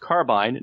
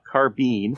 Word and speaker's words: carbine: This carbine, pronounced carbine, not carbine.